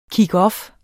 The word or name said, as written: kick